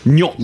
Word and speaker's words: gnocchi: Gnocchi. – Yes, double CH in Italian sound like the letter K. So gnocchi. Just replace those letters with a K and it'll make a lot more sense.